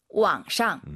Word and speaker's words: 网上: 网上. – 우리가 보통 이제 한국어로 인터넷상에서라고 할 때, 인터넷상 할때 상이 샹이라고 보시면 될것 같습니다.